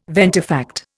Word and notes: ventifact: Indian

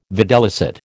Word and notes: videlicet: American English

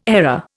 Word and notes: error: Indian

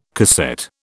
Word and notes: cassette: British English

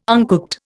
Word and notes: uncooked: Indian

